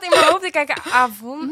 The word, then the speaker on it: Avon: Avon.